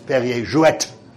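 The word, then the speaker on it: Perrier-Jouët: And it was Perrier Jouet, that's the correct pronunciation, that was the champagne of choice of Oscar Wilde.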